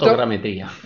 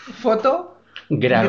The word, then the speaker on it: fotogrametría: Has dicho esa palabra que a mí me cuesta pronunciar. Foto. – Fotogrametría. – A ver, vamos por partes. Fotogrametría.